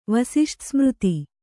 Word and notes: ವಸಿಷ್ಠಸ್ಮøತಿ: Kannada

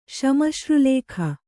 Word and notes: ಶ್ಮಶ್ರುಲೇಖ: Kannada

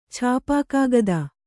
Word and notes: ಛಾಪೆಕಾಗದ: Kannada

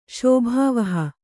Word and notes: ಶೋಭಾವಹ: kannada

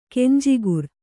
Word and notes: ಕಂಜಾಕರ: kanjākara

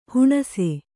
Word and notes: ಹಣ್ಣಿಸು: haṇṇisu

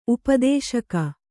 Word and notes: ಉಪದೇಶಕ: upadēśaka

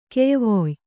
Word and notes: ಕೆಯ್ವೊಯ್: kannada

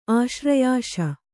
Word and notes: ಆಶ್ರಯಾಶ: āśrayaśa

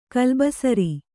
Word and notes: ಕಲ್ಲುಬಸುರಿ: kannada

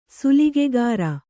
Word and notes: ಶಲಾಕಾಗ್ರ: sḷākāgra

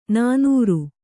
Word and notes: ನನೂರು: nanūru; kannada

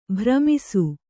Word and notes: ಭ್ರಮಿಸು: bhramisu

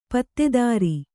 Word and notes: ಪತ್ತೆದಾರ: kannada

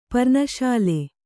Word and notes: ಪೆರೆನೊಸಲು: pere nosalu

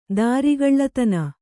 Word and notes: ದುರಗಲಿತನ: kannada

